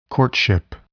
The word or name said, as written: courtship